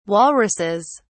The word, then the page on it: walruses: British English